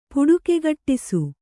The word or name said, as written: ಪುಡುಕೆಗಟ್ಟಿಸು